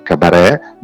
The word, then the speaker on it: Cabarè: Cabaret è scritto non con la T come cabaret, ma con l'accento sulla E. Ok, cabaret, come il cabaret delle paste.